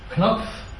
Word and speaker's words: Knopf: Knopf, or Knopf if you pronounce it in English,